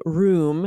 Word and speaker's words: room: I say room. It's like a hard oo, like broom, room.